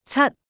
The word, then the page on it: 七: Cantonese